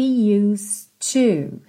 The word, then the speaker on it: used to: And we're gonna hold hands like we used to. Aqui também, ó, used to. Você não fala used, esse é não é pronunciado. E o de já faz um som ligadinho no T do to.